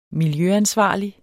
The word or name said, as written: miljøansvarlig